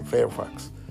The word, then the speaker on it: Fairfax: Se escribe Firefax. Se dice Fairfax.